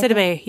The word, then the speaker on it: Sidibe: Sidibe, yeah.